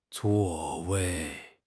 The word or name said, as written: zuo4 wei4